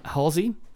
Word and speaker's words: Halsey: I think I'm saying her name right. – Yeah. – Halsey. Halsey. H-A-L-S-E-Y.